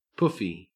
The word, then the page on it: poofy: Australian English